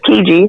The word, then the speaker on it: Tuskegee: It's stiki- st- say Tuskegee.